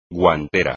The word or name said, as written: guantera